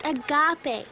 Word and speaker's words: agape: Now, that word you saw the other day is pronounced agape. It's a Greek word, and it means...